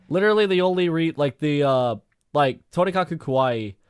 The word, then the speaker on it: Tonikaku Kawaii: Because if you look at something like Tonikaku Kawaii... I can never say that right. – Tonikaku Kawaii.